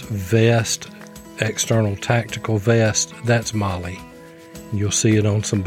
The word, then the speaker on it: MOLLE: And it's pronounced MOLLE, like the name MOLLE.